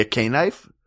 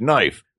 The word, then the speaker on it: knife: Like, is it actually a K-knife? Whenever a caveman first ground a rock? – I call this a K-knife. – Yeah. – You mean a knife? No, it's a K-knife. – There's a K up front, you dumb.